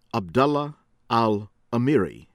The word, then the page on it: Abdullah Al Amiri: American English